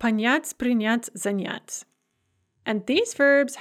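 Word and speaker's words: Panyat (понять): Panyat, to understand in the perfective, on ponyal, ana ponyala, ano ponyala, ani ponyali.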